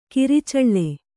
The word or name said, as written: ಕುರುಚಲು